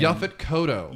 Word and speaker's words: Yaphet Kotto: Yaphet Kotto.